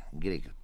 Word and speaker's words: Grieg: Así es, de Grieg. Grieg se pronuncia.